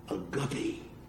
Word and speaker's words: agape: He walked up to me and he said, but he says, you're not pronouncing it correctly. And I said, oh, really? He says, no, it's agape.